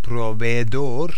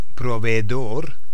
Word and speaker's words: proveedor: El proveedor de servicios. Okay, the provider of services. So the difference, provider is pronounced proveedor. So el proveedor de servicios. Just be careful with that double E in the word.